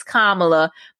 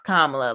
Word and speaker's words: Kamala: It's Kamala, but there's a difference between people who don't they don't know that and the people that have worked with her for years right. – and they do know it's pronounced Kamala.